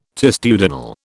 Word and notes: testudinal: British English

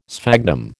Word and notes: sphagnum: American English

